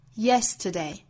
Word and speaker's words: yesterday: 这个词呢重音在第一个音节上，我们读的时候要注意节拍，三拍之中第一拍最重，我们应该把它读成 yesterday yesterday。